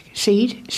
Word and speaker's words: Ceed: That's how it's pronounced. Ceed, C-E-E-D.